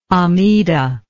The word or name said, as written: amidah